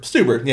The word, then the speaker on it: Stuber: Stuber, yeah, that's how you say it.